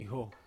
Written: ho

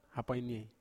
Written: hapainiei

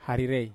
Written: harire

